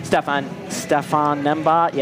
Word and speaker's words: Stefan Nembhard: Specifically, Stefan Nembhard.